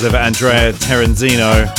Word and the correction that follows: Terenzio: I haven't pronounced that right at all, I'm so sorry. Terenzio is the last name, sorry.